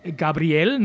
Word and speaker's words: Gabriel: Gabriel, ne, mit Betonung auf dem hinteren Vokal, richtig, weil sie Argentinier sind, aus Buenos Aires, glaube ich, ne? – Genau, aus Buenos Aires.